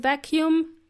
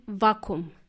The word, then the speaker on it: vacuum: There is a double letter in there, double letter U in the word vacuum, but the plumber called it vacuum. It's pronounced vacuum cleaner.